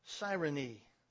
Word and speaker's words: Cyrene: He comes from Cyrene. That's how it's pronounced.